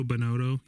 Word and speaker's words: Binotto: I brought up the pronunciation. I actually looked it up. Like, I had the you know, computer speak to me what his name was. And, yeah, that was, you know, Binotto.